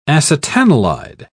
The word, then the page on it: acetanilide: British English